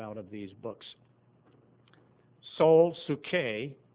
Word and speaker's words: psuche: You know, in the New Testament, the Greek word is psuche. P is silent, but it's spelled P-S-E, P-S-U-C-H-E, and C-H is kind of like a K.